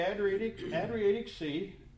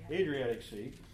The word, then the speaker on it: Adriatic Sea: He's got to sail the Adriatic Sea. I didn't say that right. Adriatic Sea.